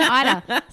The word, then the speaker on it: Eida: Sarah Eder. Eder. I keep saying Eder. Sarah Eder, and it's spelled E-I-D-A.